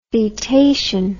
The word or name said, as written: fetation